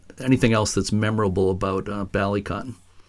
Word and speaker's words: Ballycotton: You spent time at Ballycotton. And again, please correct me if I'm pronouncing it wrong. Ballycotton Lighthouse off the south coast, southeast coast. Am I pronouncing it right? – Yes, you're pronouncing it correctly.